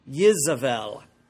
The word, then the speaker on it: Jezebel: and that person was Jezebel, pronounced in Hebrew Yezebel.